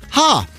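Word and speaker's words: ha (ห้า): In Thailand, the number 5 is pronounced ha, so their version of LOL is 555.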